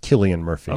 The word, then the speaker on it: Cillian: A couple people are telling us it's pronounced Killian Murphy.